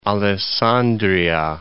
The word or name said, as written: Alessandria